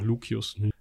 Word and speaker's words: Lucius: Und man muss immer so ein bisschen gucken mit diesem C. Eigentlich spricht man es wie K aus, aber es klingt bei Lucius einfach Lucius.